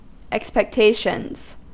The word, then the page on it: expectations: American English